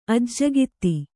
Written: ಅಜ್ಜಗಿತ್ತಿ

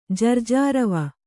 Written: ಜರ್ಜಾರವ

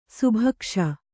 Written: ಸುಭಿಕ್ಷ

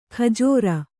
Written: ಖಜ್ಜೂರಿ

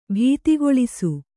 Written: ಭೀತಿಗೊಳಿಸು

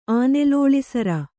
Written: ಆನೆಲೋಳಿಸರ

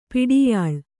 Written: ಪಡಿಯಳೆ

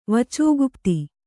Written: ವಚೋಗುಪ್ತಿ

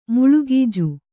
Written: ಮುಳುಗೀಜು

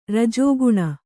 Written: ರಜೋಗುಣ

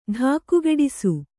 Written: ಢಾಕುಗೆಡಿಸು

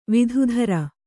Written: ವಿಧುಧರ

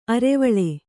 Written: ಅರೆವಳೆ

